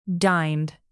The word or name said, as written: dined